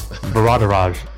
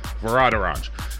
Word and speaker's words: Varadaraj: I'd say it was Veradaraj. – Veradaraj. Ajit Varadaraj Pai. I'm not good with that pronunciation, but I think I got it that time. He is an American lawyer who served as the FCC chairman from 2017 to January 2021 under the Trump administration.